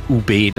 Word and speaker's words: ube: Das E wird etwas stärker betont, also UB. Die meisten sagen UB. Also korrekt wäre es UB.